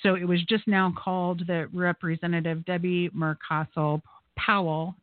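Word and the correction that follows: Mucarsel-Powell: and I always mispronounce her name, Mucarcel Powell, and that's in the 26th congressional district in Miami.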